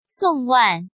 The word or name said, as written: Song Wan